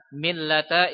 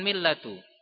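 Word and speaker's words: millata (مِلَّةَ): Sehingga oleh karena itu, antum membacanya annal hanifiyata millata Ibrahim. Harakatnya fathah, millata, bukan millatu. Karena kalau seandainya kalau antum baca millatu, maknanya bisa berubah.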